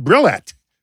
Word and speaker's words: Brillat: I've got Ben Brillat.